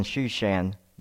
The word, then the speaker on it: Shushan: in the 20th year as I was in Shushan, the citadel." So, yes, Susa is what it looks like, but it's actually pronounced Shushan.